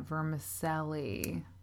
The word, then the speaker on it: vermicelli: That's how you say it, vermicelli. – I'm only saying that because I literally heard on a podcast this morning that that's how you're supposed to say it.